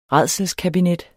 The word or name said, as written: rædselskabinet